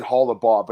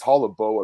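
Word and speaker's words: Holobaugh: I've been saying his name wrong for years. I guess I've always said Hollebaugh, but it's Hollebo apparently. That's the pronunciation.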